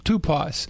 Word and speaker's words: TYPOS: And I would suggest there was a book that came out when I was at Dallas Seminary called TYPOS, which we would have pronounced it Tupos. – Right. – But it literally looks like typos.